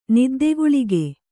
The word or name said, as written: ನಿದ್ದೆಗುಳಿಗೆ